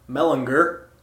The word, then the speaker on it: Mellinger: This one from respected columnist Sam Mellinger with a hard G.